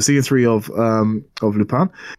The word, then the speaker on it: Lupin: They pronounce it Lupin. – Okay. – You know, because they're French.